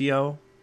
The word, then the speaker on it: IAO: It's pronounced Iao. It's spelled I-A-O.